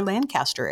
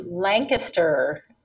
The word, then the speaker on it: Lancaster: Well, the most important thing is that um, Lancaster is not pronounced Lancaster, it's Lancaster. And the way that we know that is if somebody says Lancaster or Lancaster, then we know they're not from this area.